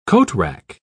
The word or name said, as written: coatrack